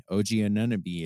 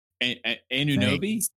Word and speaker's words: Anunoby: Have I been saying his name wrong? – Anunoby. No, I just said it really goofily. – Okay. No, my fault. I'm not trying to call you out. Yeah. I thought I was saying it wrong.